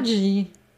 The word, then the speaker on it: Maggi: Und der Vater von diesem Julius Maggi, der war Italiener, was also eigentlich heißt, weil es gibt ja auch die italienische Schweiz, das müsste eigentlich Maggi ausgesprochen werden. Maggi.